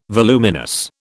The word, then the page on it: voluminous: British English